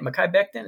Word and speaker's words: Mekhi Becton: I cannot pronounce this. Mekhi Becton. Is that how you say it? Mekhi Becton. Is that what you say? – Yeah.